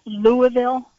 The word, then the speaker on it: Louisville: Louisville? – Yes.